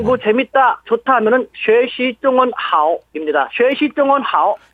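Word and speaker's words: 쉐시 (学习): 오늘은 중국어 공부 좋다, 중국어 공부 재미있다라는 식으로 해갖고 학습 중문인데 공부하다, 학습하다, 배우다를 중국어로 학습이라고 합니다. 영어 아 우리말 한자 학습을 써가지고 쉐시 쉐시라고 읽고요.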